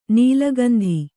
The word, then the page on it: ನೆಲೆಗುಂದು: nelegundu